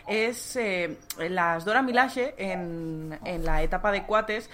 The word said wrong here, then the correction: Coates: quieren, bueno, de Coates,